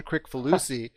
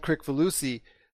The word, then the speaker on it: Kricfalusi: I say this with a hard C, so I apologize, John Kricfalusi, which I'm sure everybody else says it that way too, but I noticed you said it, Ron, with a soft C, John Kricfalusi.